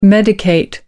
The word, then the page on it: medicate: British English